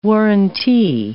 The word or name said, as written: warrantee